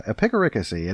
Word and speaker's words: epicaricacy: It is spelled E P I C. Uh, epic. A R I, and then C A C Y, and the stress is on the third syllable, so it's like a picaricacy.